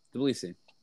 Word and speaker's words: Tbilisi: I never pronounce it correctly because it has like two consonants at the beginning. – That's okay. – Tbilisi. – That is your final answer? Question mark. – Wait. Yes. – Yes, that's correct. – Oh, how do you pronounce it? – Tbilisi. – Yeah, that was close.